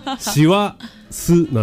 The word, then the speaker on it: 師走: はい、師走は師走と読むけど、どこで切るんだと。 – そうだ、調べてない。 – しわすなのか、しわすなのか、全然わかんないね。 – わかんない。 – あの二文字でああやって書いて初めてしわすと読む、単体ではそうは読まないで、わはどこなんだろう。